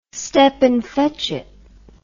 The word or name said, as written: stepin fetchit